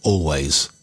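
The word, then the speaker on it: Alvvays: Now, here's a tasty little tune from Canada, an indie band called Always, as in A-V-V-A-Y-S, but pronounced Always.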